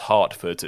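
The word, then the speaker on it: Hertford: That's H-E-R-T-F-O-R-D. – Cool. – Hartford. But it's pronounced Hartford, so it sounds like it's pronounced with an A, but it's pronounced with an E.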